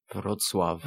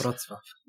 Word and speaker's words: Wrocław: Wolklaw. Well, sorry, how do you pronounce that city? – Wrocław. – Yeah. – Yes. – Wrocław.